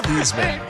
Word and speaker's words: Theismann: No one besides Joe Theismann, pronounced Theismann, has ever said that.